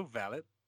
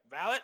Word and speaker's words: valet: I think it's because it's, like, right after World War II, and we're, like, we're not gonna use the French pronunciation of anything over here in America. We're going to say valet.